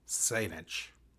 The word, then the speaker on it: Saynisch: But our guest today is Philip Saynisch. Did I say it right, Philip? – Yep. – And he is a doctor. Dr. Saynisch is one of our esteemed staff here at NCQA.